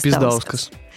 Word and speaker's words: Пиздаускас: Пиздаускас. – Ударение правильное.